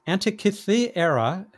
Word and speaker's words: Antikythera: The Antikythera me- mechanism. The Antikythera mechanism. Can you help me with pronunciation there? – Well, here's how Dave Fairchild, our poet laureate, put it: Antikythera. No, I have to get the emphasis right.